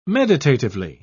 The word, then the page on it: meditatively: British English